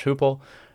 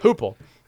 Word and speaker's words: Heupel: in Josh Heupel.